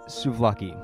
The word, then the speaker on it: Souvlaki: Souvlaki is the correct way to pronounce it.